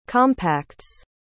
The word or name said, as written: compacts